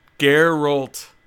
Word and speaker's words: Geralt: Right. Yeah. Gerald? – Geralt. – Geralt. – Hard G.